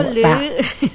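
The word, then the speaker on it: leuit: Kalau bahasa Sunda itu leit. Kalau E dan U itu dibacanya le gitu loh. Leit.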